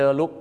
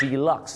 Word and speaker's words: deluxe: ไปบอกฝรั่งคันแฮปเปอร์เดอร์ลุคเขาออกเสียงว่า deluxe นั่นเองครับ deluxe นะฮะไม่ใช่เดอร์ลุคนะครับ Deluxe